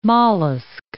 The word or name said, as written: mollusk